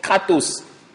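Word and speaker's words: kratos (κράτος): Le mot retiens ici est un mot grec que l'on prononce kratos. K-R-A-T-O-S.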